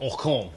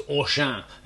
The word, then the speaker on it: Auchan: There's lots to discuss, but I probably should start off by saying I don't think it's called Auchan, the French retailer. My French pronunciation was awful. I think it's Auchan. There you go. That's much better, isn't it?